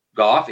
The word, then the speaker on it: Gough: In this case, Gough is pronounced Gough.